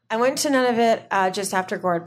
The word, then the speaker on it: Nunavut: Oh, Nunavut, yes.